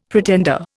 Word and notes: pretender: Indian

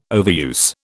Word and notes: overuse: British English

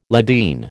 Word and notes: Ladin: American English

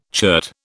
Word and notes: chert: British English

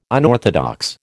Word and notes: unorthodox: American English

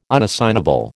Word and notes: unassignable: American English

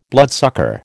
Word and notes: bloodsucker: American English

